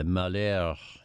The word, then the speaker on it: Malheur: The group that occupied the Malheur National Wildlife Refuge. I give it the French pronunciation because it means unhappiness.